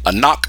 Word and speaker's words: Ankh: And they spell it A-N-K, and we spell it A-N-K. We just pronounce it different. We say Anak, they say Ankh.